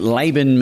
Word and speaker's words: Laban: But Laban means yogurt in Arabic, Labna. But it also means to show fight in the main language in the Philippines. So, and it's pronounced Laban, yeah.